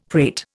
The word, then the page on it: prate: Indian